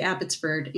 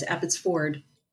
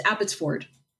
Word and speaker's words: Abbotsford: And, you know, on the U.S. side, we all say Abbotsford. In Canada, it's Abbotsford. It's Abbotsford. That's how you say it.